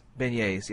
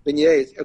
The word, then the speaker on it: beignets: Y'all have been eating, I hope I don't mispronounce it, beignets? – Beignets, yeah. – Beignets, okay.